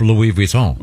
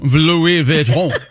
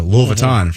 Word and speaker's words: Louis Vuitton: Because it's Louis Vuitton. – Louis Vuitton. – I know. – I say it like Louisville. I go, I'm getting a Louis Vuitton for my baby.